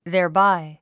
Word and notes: thereby: ðὲə r bái; ゼアバイ

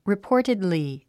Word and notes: reportedly: ripɔ́ːrtidli; リィポォテドリィ

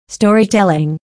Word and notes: storytelling: stɔ́ːritèliŋ; ストーリィテリン

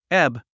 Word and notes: ebb: éb; エブ

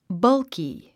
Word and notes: bulky: bʌ’lki; バルキィ

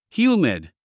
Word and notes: humid: hjúːmid; ヒューミド

